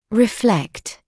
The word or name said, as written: reflect